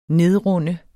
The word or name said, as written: nedrunde